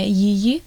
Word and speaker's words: Yiyi: Allora, mio fratello maggiore si chiama Yi, però in realtà credo si pronunci una cosa come Yi, e praticamente si scrive Y-I-Y-I.